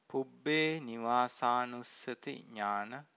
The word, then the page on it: pubbenivasanussati-nana: Pali